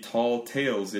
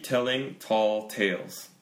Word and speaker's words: tall tales: Why do you keep telling tall tales? 발음이 어렵죠. – Tall tales. – 네, telling tall tales. 그러니까 모음 계속 달라지잖아요. T 세 번 들어가지만 그 모음 소리 이렇게 계속 달라져요.